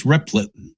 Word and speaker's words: Replit: This attempt to pronounce it Replit. Is that correct? Replit? – I think it's Replit.